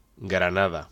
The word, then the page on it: Granada: Spanish